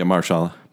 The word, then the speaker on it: Mahershala: But I think Mahershala, is that how you say it? – Yeah, Mahershala.